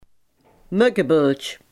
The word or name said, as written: muggenbult